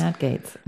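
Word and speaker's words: Gaetz: ich, dachte immer, es wird Gates ausgesprochen, aber Gates. Gates. Matt Gates.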